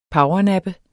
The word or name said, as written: powernappe